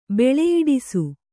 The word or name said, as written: ಬೆಳೆಯಿಡಿಸು